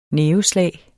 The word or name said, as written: næveslag